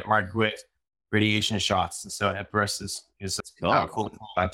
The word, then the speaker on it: Epirus: Epirus? – Epirus was the name of the bow of Theseus, infinite heroes in legend.